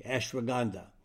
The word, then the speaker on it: ashwagandha: Now ashwagandha is spelled A like an apple, S like Sam, H-W-A-G-A-N-D-H-A, ashwagandha.